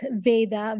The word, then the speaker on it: Veda: It's Veda, V-E-D-A, Baldota.